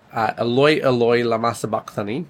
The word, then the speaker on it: Eloi, Eloi, lama sabachthani: Jesus is then going to get to the point where he cries out and says, Eloi, Eloi, lama sabachthani.